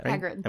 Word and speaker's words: Hagrid: Hagrid? – Hagrid. – Am I saying that right? – Yeah, you're doing it. You got it.